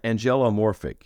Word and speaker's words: angelomorphic: So you start your discourse with, and I'm probably not going to pronounce this right, angelomorphic, is that right? – Yeah, angelomorphic, yeah. – Angelomorphic. – There's an O sound in there. Angelomorphic, yeah.